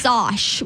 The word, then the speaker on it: Sosh: Sosh with an O. S-O-S-H. The Sosh.